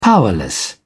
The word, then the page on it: powerless: British English